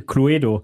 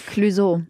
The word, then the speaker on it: Clueso: Ich hatte am Anfang immer Probleme, weil ich dachte, er wird Clueso, also wie das Spiel, Cluedo, ausgesprochen. Aber ich habe es zum Glück nie on air gesagt. – Ja, Clueso.